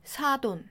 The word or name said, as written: 사돈